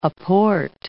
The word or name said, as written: aport